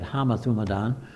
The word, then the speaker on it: homothumadon: The Greek that's missing is the word homothumadon. That's the way we'd say it. The Greeks would say it homothumadon. But it's spelled like homo, H-O-M-O, which means same, similar, like in English and in Greek as well.